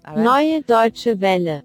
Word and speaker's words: Neue Deutsche Welle: Neue Deutsche Welle.